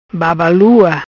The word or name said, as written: Babalua